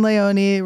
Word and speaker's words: Leone: Damien Leone rhymes with baloney. How funny is that he's had to say that? He said it to me. I went, oh, I've been calling him Leone.